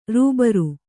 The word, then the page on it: ರಬ್ಬರು: rabbaru